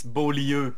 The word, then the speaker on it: Beaulieu: It's Beaulieu, but I think the U and the U in English are pronounced pretty much the same, so I'm not going to be that picky and give you a 9 out of 10.